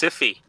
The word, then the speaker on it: Syfy: Then there, speaking of Star Wars, there's the Sci-Fi Bartow, but it's spelled S-Y-F-Y, so I wonder if it's actually put on by the TV channel? – The network, yeah. – That's pronounced Siffy. – Yes, it is, because it's not actually the Sci-Fi channel.